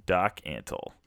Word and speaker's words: Antle: So I just found that just very interesting, especially like Doc Antilles. How do you say his name? Antilles? Let's see. Doc Antle.